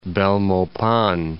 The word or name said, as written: Belmopan